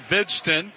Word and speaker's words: Hvidsten: 1-0 the count to Vidston. The H is silent. His name is spelled H-V-I-D-S-T-E-N.